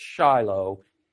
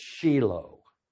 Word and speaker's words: Shiloh: I hate to tell you, folks, it's not pronounced Shiloh. In Hebrew, it's Shiloh. Shiloh sounds more euphonic, I give you that. But it's the proper Hebrew pronunciation is Shiloh.